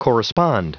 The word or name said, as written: correspond